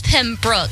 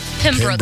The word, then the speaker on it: Pembroke: Why can't I say Pembroke? – Because it's Pembroke. It's and Pembroke. We're gonna shout them out. I'll make sure we say it right. Pembroke.